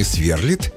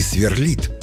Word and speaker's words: сверлит: Орфоэпический словарь русского языка как государственного сейчас разрешает оба варианта. При этом некоторые словари рекомендуют ставить ударение на последний слог – «сверлит». Но на самом деле, запоминаем, оба варианта правильные – и «сверлит», и «сверлит».